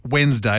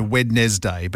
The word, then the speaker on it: Wednesday: And the last one, Wednesday doesn't have 3 syllables. So it is just Wednesday as opposed to Wednesday.